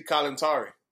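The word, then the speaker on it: Kalantari: And, I mean, you have just, I mean, the number of people you work with, Lucy Calentari, I always mispronounce her name, Calentari. – Yeah, Lucy Calentari.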